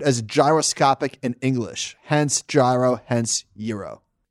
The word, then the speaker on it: gyro: So last week we spoke about gyro and how can it possibly be pronounced gyro, which doesn't compute. Well, here's why. Greeks say the G differently than we do in English.